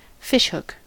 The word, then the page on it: fishhook: American English